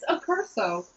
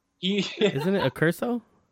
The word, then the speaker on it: Accurso: It's so much easier to spell, like A-C-C-U-R-O-S-O or O-S-A. How would you say that, like Italian? Accuroso. – It's Accurso. – Anyway, we have a hard time saying it. But isn't it Accurso? – Yeah, Accurso. – Did you just get it right? – U-R-S-O.